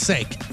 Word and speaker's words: Saak: His name is Brett S-A-A-K, pronounced Sake. – Sake. – Okay, yeah, Sake. – But how do you spell Saki? – S-O-C-K-E-E.